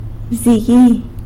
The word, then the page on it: bzigui: sanlucasquiavini